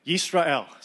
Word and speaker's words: Yisrael: Second word, it's Israel with a Y in front, and you say it like Yisrael. So everyone say Yisrael. – Yisrael.